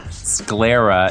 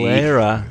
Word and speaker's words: sclera: That's a hard C. – Sclera. – It's a hard C. – Sclera.